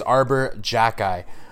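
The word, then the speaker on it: Xhekaj: And big shout out to our managing editor, Justin Blades, for telling me how to pronounce that, because honestly, I was looking at his name. It's spelled X-H-E-J-A-K. I mean, there's no way I was going to figure out how to pronounce that without some assistance. But it is Arbor Jacki.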